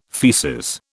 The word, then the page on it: faeces: British English